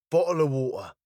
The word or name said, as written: bottle of water